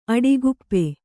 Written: ಅಡಿಗುಪ್ಪೆ

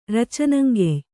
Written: ರಚನಂಗೆಯ್

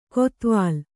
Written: ಕೊತ್ವಾಲ್

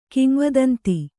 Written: ಕಿಂವದಂತಿ

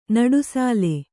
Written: ನಡುಸಾಲೆ

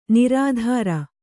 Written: ನಿರುತ್ತರೆ

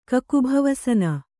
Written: ಕಕುಭವಸನ

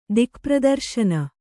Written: ದಿಕ್ಪ್ರದರ್ಶನ

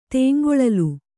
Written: ತೇಂಗೊಳಲು

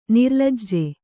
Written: ನಿರ್ಲಜ್ಜೆ

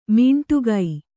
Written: ಮೀಂಟುಗೈ

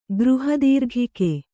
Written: ಗೃಹದೀರ್ಘಿಕೆ

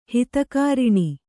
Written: ಹಿತಕಾರಿಣಿ

